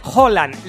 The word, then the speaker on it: Haaland: Se dice Haaland. La doble A en noruego es A, que se pronuncia O.